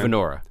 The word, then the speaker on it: Venora: Well, one thing I thought was funny, interesting in the movie, and they do back it up later, is that Diane, how you say her name? – Venora. – Venora offers him a cookie.